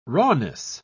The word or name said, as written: rawness